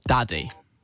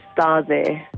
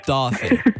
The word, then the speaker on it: Daði: And then there's another D with a line through it, and that's pronounced as a TH. – So it's not pronounced Daddy? – No, it's not. It's Daddy. – Thank you very much. – You would say it D-A-T-H-I, essentially.